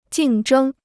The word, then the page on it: 竞争: jìng zhēng